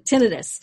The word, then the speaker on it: tinnitus: Tinnitus? I never pronounce that right. – Tinnitus.